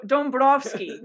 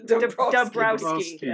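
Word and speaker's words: Dąbrowski: Oh, Dombrowski. Dombrowski, as it's written.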